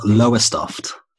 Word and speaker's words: Lowestoft: It's called Lowestoft.